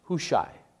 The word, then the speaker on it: Hushai: And Hushai is pronounced Hushai. Hushai.